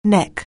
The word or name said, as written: neck